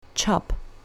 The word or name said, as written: chop